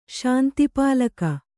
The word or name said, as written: ಶಾಂತಿಪಾಲಕ